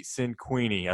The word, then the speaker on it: Cinquini: Um, we have with us Jesse Cinquini.